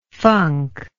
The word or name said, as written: funker